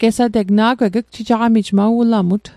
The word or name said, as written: ugjijaqamijl